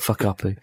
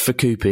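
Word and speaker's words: Fukuppy: Good old Fuck Uppy. Although, to be fair, it is pronounced Fukupi, isn't it, in Japanese, so it's just, yeah.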